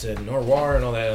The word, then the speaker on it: noir: It is the urban fantasy, and it does get into the noir. Is that the right way to say it? – Noir. Sure. – Noir.